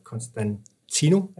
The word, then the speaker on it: Constantino: Og øh, nu prøver jeg jo at finde ud af, hvad det er, det er, du laver sammen med Andreas øh, Constantino. Er det rigtigt udtalt? – Ja, det er ja. rigtigt.